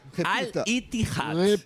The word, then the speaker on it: Al-Ittihad: Ele estreou no Al-Ittihad. – Como é que é? – Al-Ittihad. Essa é a pronúncia.